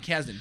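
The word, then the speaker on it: Kasdan: But showrunner John Kazan or Kazdan? Is that right? – John Krasinski? – John Kazdan, I think is how you pronounce his last name. – John Kazdan. – Kazdan, yes.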